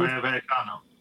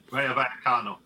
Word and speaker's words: Rayo Vallecano: Rayo Vallecano. – Yeah. – Rayo Vallecano.